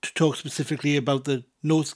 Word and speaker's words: Nos: First word is Nos, night, spelled N-O-S, Nos. So you were just saying the word no with a s sound at the end of it. Nos. Then we have Galan, spelled G-A-L-A-N, pretty much pronounced as you spell it.